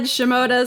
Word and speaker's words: Shimota: Shimota is correct.